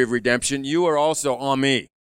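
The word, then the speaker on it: Ami (עמי): Ami, when you put a long E on the end of it, it's my people.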